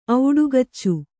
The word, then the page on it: ಔಡುಗಚ್ಚು: auḍugaccu